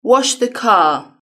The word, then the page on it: wash the car: British English